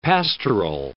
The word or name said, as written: pastoral